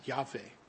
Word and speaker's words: Yahweh: I mean, there isn't a W sound in Hebrew, so I'm not sure why we say Yahweh, because the W sound isn't there, right? It would have to be more like a V. And I know people who do pronounce it with the V sound, Yahweh.